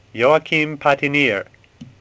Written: Patinir

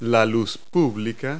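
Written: streetlight

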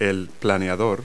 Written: glider

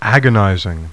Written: AGON

